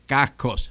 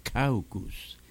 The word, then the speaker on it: caucus: Mire, un momento, no se dice cascos, se dice caucus.